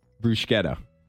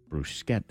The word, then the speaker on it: bruschetta: Bruschetta. – like a brew. – Bruschetta.